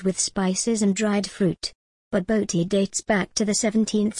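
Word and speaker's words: Bobotie: Baboti, pronounced Baboti, is a Cape Malay creation made with minced beef and topped with a savory egg custard.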